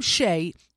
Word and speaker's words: Skjei: Brady Shea is a player for the Rangers, and his last name, the last name Shea, is spelled S-K-J-E-I. – No. – Correct. – No. – S-K-J-E-I.